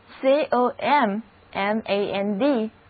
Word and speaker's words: command: 得到了这个单词 COM MAND command 的意思就是命令、指挥。 – 字母 A 的读音是长的 R，第二个音节是重读音节，所以整个单词读作 command， command。